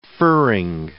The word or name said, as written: furring